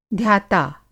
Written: ध्याता

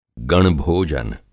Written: गणभोजन

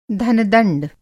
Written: धन-दंड

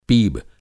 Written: पीब

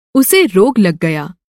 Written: रोग

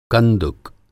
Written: कंदुक

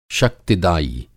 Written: शक्तिदायी

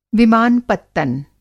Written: विमान-पत्तन

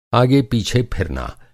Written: आगे-पीछे

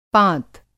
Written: पाँत

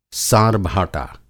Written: सारभाटा

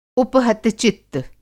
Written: उपहतचित्त

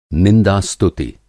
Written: निंदा-स्तुति

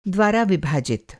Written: विभाजित

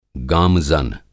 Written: गामज़न

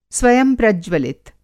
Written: स्वयंप्रज्वलित

